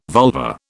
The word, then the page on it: vulva: British English